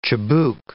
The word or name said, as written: chibouque